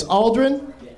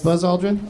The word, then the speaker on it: Aldrin: And it's Aldrin. I'm saying it right? Like the astronaut? – Buzz Aldrin?